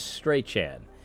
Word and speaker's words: Strachan: Well, this is Strachan. Someone told me that's how you pronounce his name. I've got it on good authority. That's how it is, Owen Strachan.